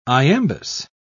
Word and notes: iambus: British English

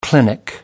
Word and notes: clinic: American English

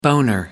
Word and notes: boner: American English

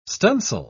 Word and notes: stunsail: British English

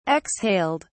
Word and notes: exhaled: British English

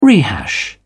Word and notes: rehash: British English

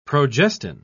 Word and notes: progestin: British English

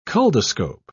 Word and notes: culdoscope: British English

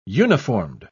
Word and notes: uniformed: British English

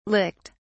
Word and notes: licked: British English

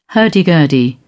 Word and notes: hurdy-gurdy: British English

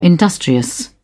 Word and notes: industrious: British English

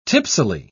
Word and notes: tipsily: British English

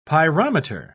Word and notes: pyrometer: British English

